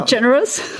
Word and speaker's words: DeGeneres: Ellen ja. DeGeneres. – Ja, hast du richtig gesagt.